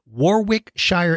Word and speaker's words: Warwickshire: Warwickshire, England.